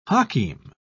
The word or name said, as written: hakim